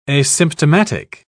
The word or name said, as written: asymptomatic